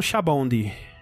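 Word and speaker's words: Sabaody: Ah, em, em japonês eles falam shabondi.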